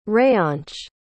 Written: reyansh

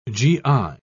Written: gi

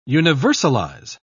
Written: universalize

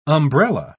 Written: umbrella

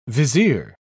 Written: vizier

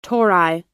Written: tori

